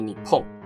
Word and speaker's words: 日本: 例如大家熟悉的日本银行和日本邮政这两个，他们名字中的日本就只能念作你碰。